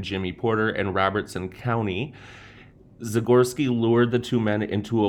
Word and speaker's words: Zagorski: Okay, so while Liz looks at that, we're gonna move on to Edmund Zagorski, I believe is how you say it, Z A G O R S K I, o r s k i.